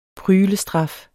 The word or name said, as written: pryglestraf